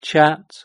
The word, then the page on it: chat: British English